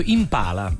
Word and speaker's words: Impala: Allora, intanto è proprio Impala.